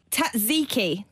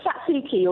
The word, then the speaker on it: tzatziki: It's like tzatziki or tzatziki. We struggle with that because we don't do tsut. We don't do that sound at the beginning of a syllable.